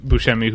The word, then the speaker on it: Buscemi: And did I pronounce that correctly? – You did. – Oh, good. Because I know there's, of course, the actor Steve Buscemi, who some people say Buscemi, Buscemi. – I locked into Buscemi, and I've heard people that have like worked with him say Buscemi, and like, I'm not doing that.